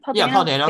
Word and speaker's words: Dano: Paul Dano.